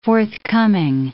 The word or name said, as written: forthcoming